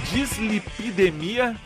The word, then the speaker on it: dislipidemia: Dislipidemia familiar. Falei certo? – Falou certo.